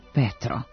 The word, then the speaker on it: Petro: Po ruskom modelu Petar i po ukrajinskom izgovoru Petro.